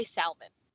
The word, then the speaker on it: salmon: salmon, you pronounce the L in salmon. So you say salmon.